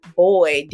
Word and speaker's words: Boyd: My name on all social media platforms is Diane Boyd, D-I-A-N-E, last name B-O-Y-D.